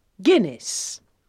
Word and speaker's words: Guinness: That's pronounced Guinness. Try and say it. – Guinness.